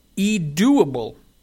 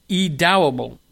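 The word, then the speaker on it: Edoughble: And one of them that came on board that's included is a brand called E-Doable. E-Dowable.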